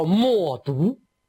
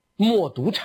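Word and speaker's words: 冒顿: 这个人就是在匈奴历史上非常有名的一个单于，叫莫毒，莫毒单于。这两个字的千万不要读错，莫呢很容易读成冒犯的冒，这个毒呢很容易读成停顿的顿，莫毒单于。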